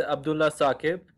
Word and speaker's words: Abdullah Saqib: And yeah, it's Abdullah Saqib, but you pronounce it quite accurately anyways. So I'm Abdullah Saqib from Islamabad, Pakistan.